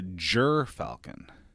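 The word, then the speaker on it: gyrfalcon: So it's called a Jir falcon.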